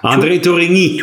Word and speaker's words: André Tourigny: André Tourigny.